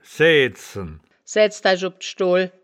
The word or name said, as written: saeaedzen